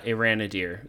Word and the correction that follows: Arondir: there. Okay. Or did I say it right? I'd say Arondir. – Um, I think that's how you say it. Arondir.